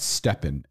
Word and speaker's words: STEPN: It's called Stepn. S-T-E-P-N. Stepn. Okay. – Spell it again. – S-T-E-P-N.